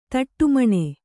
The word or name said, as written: ದುಢುಮ್ಮನೆ